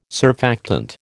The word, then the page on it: surfactant: American English